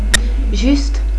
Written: Juste

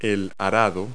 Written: plow

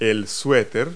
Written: sweater

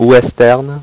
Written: western

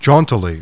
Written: JAUNTILY

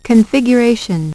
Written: configuration

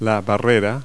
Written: barrier

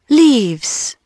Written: leaves

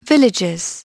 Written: villages

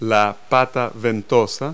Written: proleg

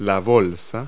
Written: pouch

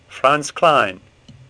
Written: Kline

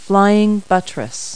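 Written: flying buttress

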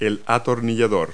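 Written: screwdriver

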